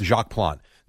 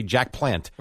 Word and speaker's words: Jacques Plante: Jacques Plante. There were, look, you actually pronounced it wrong. – Yeah, good job. – It. – You didn't say Jack Plant.